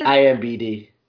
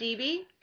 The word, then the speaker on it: IMDb: IMBD.